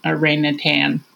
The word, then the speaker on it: orangutan: And he said, "It looked like a baby orangutan." And I was like, "Exactly." I know I didn't say that right. Orangutan. – Yeah, there's no G at the end.